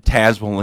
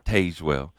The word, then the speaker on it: Tazewell: And thank goodness you said Tazewell and not Tazewell.